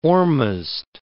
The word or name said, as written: Ormazd